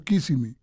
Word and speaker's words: Kissimmee: ¿Kissimmee o Kissimmee? – Kissimmee. – Kissimmee. Ah, oh, bueno, quiere conocer es. Kissimmee, que se escribe K-I-S-S-I-M-M-E-E.